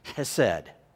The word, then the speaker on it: chesed: This is that powerful word, chesed. Deal kindly is chesed. That's the word pronounced with our English style to it.